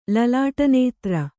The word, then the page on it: ಲಲಾಟನೇತ್ರ: kannada